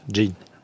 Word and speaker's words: Gen: Benoît Peters, dites-moi si ça peut se dire à quoi ressemble cette américaine prénommée Jean, ce qui s'écrit Jean comme en français, G-E-N, et se prononce Jean.